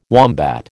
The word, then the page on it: wombat: American English